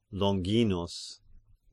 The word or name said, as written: Longinus